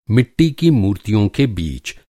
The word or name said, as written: मूर्ति